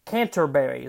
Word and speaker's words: Canterbury: Canterbury is how you say it.